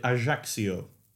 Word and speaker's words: Ajaccio: Apparently, the French call it Ajaccio.